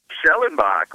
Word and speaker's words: Schellenbach: Schellenbach,